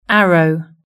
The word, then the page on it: arrow: British English